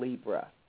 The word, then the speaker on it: Libra: I'm going to speculate that it's in Libra. I think it's in the sign of Libra. Some people pronounce that sign Libra, but however you pronounce it, it's the same.